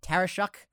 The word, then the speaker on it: Tarashuk: But it's always good to ask, like my last name, Tarashuk.